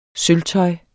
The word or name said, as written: sølvtøj